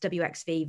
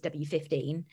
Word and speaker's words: WXV: I think we're going to clash over the next couple of years about if it's pronounced WXV versus W15. I'm going W15. I don't understand how anyone can think it's WXV, but we'll leave that by the by.